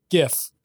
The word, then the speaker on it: GIF: Stop calling it GIF. It's GIF with a hard G by at BH Rome at CM World.